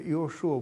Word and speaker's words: Иошуа: Ну вот Иисус, кстати говоря, латинизированная форма Иисус стала очень, так сказать, популярным, но на самом деле это имя произносится Иошуа, Бог спасет, потому что как Иоанн, знаменитый Иван русский, происходит от имени Иоханан, Бог, Божья благодать.